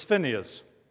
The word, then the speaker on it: Phinehas: Someone said you pronounce that Phinehas. I said the H is silent. It's Phinehas. Anyway.